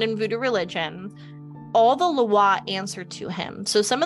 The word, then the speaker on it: Loa: It's Loa. So it's like, it's pronounced like LWA.